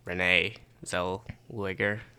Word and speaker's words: Zellweger: And then Best Actress, Renee Zellweger. I think is that her name pronounced? – Yeah.